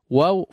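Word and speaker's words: wa (وَ): Tapi ini ketika awal saja, ketika pengucapan ketika berharakat fathah dia akan terbuka wa, ya, wa, bukan wo di depan, ya, tapi wa, ya, dibuka fathah wa wa.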